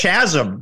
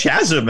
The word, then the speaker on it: chasm: He wants chasm. Who uses chasm in their everyday language? – But anyway, chasm or chasm? – It's hard C sound there.